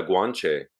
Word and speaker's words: Guanche: And I tried to find out more about the kind of, there wasn't much, like, available about the kind of the Gauche people. I don't know if I'm pronouncing it correctly. – Yeah, the Guanche. – That's it.